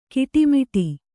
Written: ಕಟ್ಟುಮುಡಿ

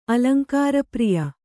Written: ಅಲಂಕಾರಪ್ರಿಯ

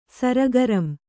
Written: ಸರಗರಂ

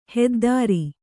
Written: ಹೆದ್ದೆರೆ

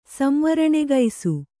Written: ಸಂವರಣೆಗೈಸು

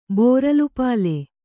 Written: ಬೋರಲುಪಾಲೆ

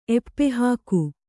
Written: ಎಪ್ಪೆಹಾಕು

